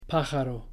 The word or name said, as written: pájaro